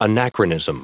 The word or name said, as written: anachronism